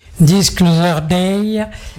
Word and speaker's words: Disclosure Day: Disclosure Day.